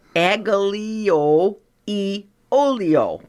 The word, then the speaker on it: aglio e olio: Well, how do you say it? Aglio e olio. – Perfect.